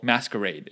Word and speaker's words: MSQRD: So a short time after Snapchat came out with filters, I remember discovering a new face filter app called Masquerade. It's spelled M-S-Q-R-D.